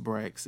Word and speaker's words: BRX: And this week's episode is sponsored by Brax Candles, BRX, pronounced Brax.